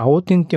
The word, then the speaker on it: 青天: これ青天って読むんだ。 – 青天って読むんですよ。